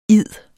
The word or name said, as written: id